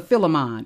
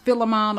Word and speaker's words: Philemon: Read Philemon verse 18, or either Philemon. It's either pronounced Philemon or Philemon.